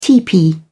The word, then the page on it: tepee: British English